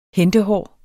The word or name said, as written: hentehår